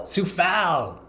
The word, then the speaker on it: Soufal: I noticed that Vladimir Soufal, if I'm saying his name correctly. – Soufal. Yeah.